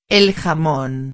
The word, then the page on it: el jamon: espanhol